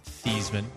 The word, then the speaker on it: Theismann: You've also got Joe Theismann. Should be pronounced Theismann.